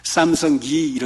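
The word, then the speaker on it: Samsung: 브라질에서는 삼성을 발음이 끝에가 S-U-N-G로 끝나잖아요. G로 끝나면 브라질은 D로 끝나요, 발음이. 삼성기 이렇게 발음을 합니다.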